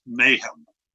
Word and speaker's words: Nahum: Now, Semitic languages don't use vowels. They only use consonants. And so NHM is actually pronounced Nahum.